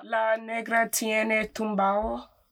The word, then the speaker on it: La Negra Tiene Tumbao: Like Celia Cruz wrote a song called La Negra Tumbao. Am I saying it right? – La Negra Tumbao. – La Negra Tiene Tumbao. – Oh, la negra tiene tumbao. – Yes, you said it.